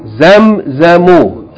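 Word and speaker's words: zamzam (زمزم): Zamzamu. – Zamzamu. Zamzamu. Naam. Everybody knows this word, right? If you don't pronounce the last vowel, zamzam is the water.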